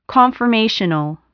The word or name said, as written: conformational